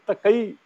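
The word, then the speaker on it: तक़ी: या देखो मैं मीर तकी मीर बोल गया था, आपने मीर तकई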